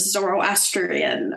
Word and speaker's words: Zoroastrian: They're like, we used to be Zoroastrians, and then the Arabs came in with Islam.